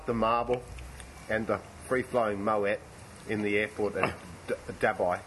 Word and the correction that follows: Dubai: Dabai? Is that the new pronunciation, is it? – I didn't know there was any other. I'd like to dab my eye in the... – Dubai.